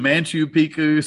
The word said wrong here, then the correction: Machu Picchu: It's Machu Picchu. – Machu Picchu. – Yes. Yeah, that's how you say it.